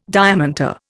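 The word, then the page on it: diamanté: Indian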